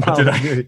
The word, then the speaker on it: Molyneux: Stefan Molyneux. No, I don't know how to pronounce it. How do you say his last name? – You got it right. That's right. Stefan Molyneux.